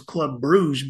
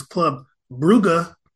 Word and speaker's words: Club Brugge: I thought it was Club Bruges, but it's Club Brugge against Atletico Madrid.